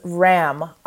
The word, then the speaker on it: ram: This chakra, the sound it makes is ram, R-A-M, like the animal ram. Mm-hmm. So ram. Again, emphasis on the M, because that's where I can feel it.